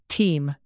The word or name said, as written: teem